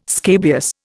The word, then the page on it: scabious: Indian